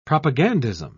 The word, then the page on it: propagandism: British English